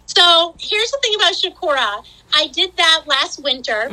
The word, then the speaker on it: Chocorua: But I was reading this morning about Chocorua. Did I say that right? – Chocorua, yes.